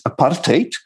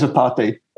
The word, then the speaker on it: apartheid: Okay, so it's an Afrikaans word. The Afrikaans pronunciation is apartheid, and it's been anglicized in Britain and elsewhere as apartheid, but it literally means separateness.